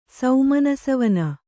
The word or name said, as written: ಸುಮನಸಾವನಿ